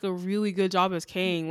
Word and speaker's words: Kang: Yeah, Kang.